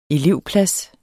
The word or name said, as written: elevplads